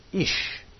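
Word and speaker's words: Ish (אִישׁ): This word is pronounced Ish.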